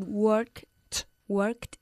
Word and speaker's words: worked: If you had worked harder. – Eso es, worked. Don't forget that T sound at the end.